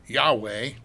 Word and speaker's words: Yahweh: What is Jehovah or Yahweh, depending on how you want to pronounce it with the vowel points, what does that mean?